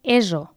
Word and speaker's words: ESO: Sí, i a més els de secundària hem de dir que estudien ESO, pronunciat amb una S sonora, no amb la forma castellana que habitualment sentim, que és ESO.